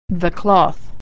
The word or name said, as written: cloth